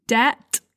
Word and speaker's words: debt: If you Google a word, for example, debt, that's a commonly mispronounced word. Don't pronounce the B, debt.